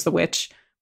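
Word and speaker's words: The VVitch: I saw the Vivitch. I pronounce it the Vivitch, but it's just the witch.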